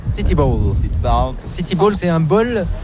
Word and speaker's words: bowl: Ça prononce comme bowl, mais c'est bowl. – Bowl, mais c'est pas comme un bol. – C'est B-O-W-L.